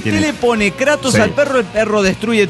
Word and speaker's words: Kratos: Kratos, se pronuncia Kratos.